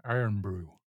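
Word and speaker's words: Irn-Bru: It's spelled IRNBRU, Iron Brew.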